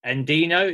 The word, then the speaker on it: Endino: Barrett talks a lot in his book about Jack Endino.